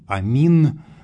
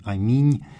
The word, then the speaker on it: аминь: Мы могли бы произносить «амин», но это просто случайность такая, что вариант с мягким знаком на конце остался в употреблении. «Аминь», очевидно, что произносилось это когда-то как «амине», то есть вот краткий гласный на конце звучал.